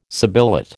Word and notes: sibilate: American English

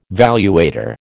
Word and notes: valuator: American English

